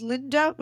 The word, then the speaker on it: Lynda: My name is Linda with a Y, L-Y-N-D-A, and my surname is Cramer, C-R-A-M-E-R. So put me into Google, you find my links.